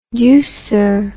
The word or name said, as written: deucer